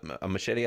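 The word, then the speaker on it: Muschietti: Machete, I think is how you say his last name, was the guy that did It.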